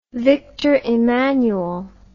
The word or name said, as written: Victor Emmanuel